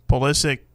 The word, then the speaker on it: Pulisic: It looked like Christian Pulisic put it in.